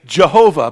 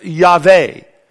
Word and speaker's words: Yahweh: We incorporate those vowels so we can pronounce it by saying Jehovah, but in Hebrew it's pronounced Yahweh. That's who he is.